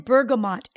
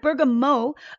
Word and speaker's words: bergamot: You could call it bergamot, but I think it's also bergamot, M-O-T at the end. If you're looking for the essential oils when you go to your local store, it's spelt with an M-O-T, right? But it's also pronounced it is, bergamot. It is, yeah, I know. It's not spelled the way it's said.